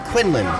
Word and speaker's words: Quinlan: So, Glenda Rhodes from Quinion, if that's how you pronounce that. No, sorry, Quinlan. My bad. – Quinlan, yep.